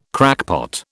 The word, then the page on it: crackpot: British English